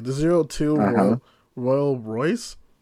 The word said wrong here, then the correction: Rolls Royce: Rolls Royce. The vehicle. Royce. The car. – My bad. I always pronounce that fucking shit wrong.